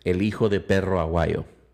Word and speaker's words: El Hijo de Perro Aguayo: El Hijo de Perro Aguayo.